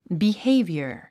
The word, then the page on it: behavior: bihéivjə r; ビヘイビィヤ